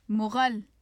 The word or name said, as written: mughal